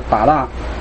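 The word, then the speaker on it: 巴 拉 (בָּרָא): 起初神创造天地，那这个创造这个字它的读音是巴拉。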